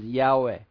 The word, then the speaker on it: Yahweh: The pronunciation is not exactly known, but they figured out it's probably pronounced as Yahweh.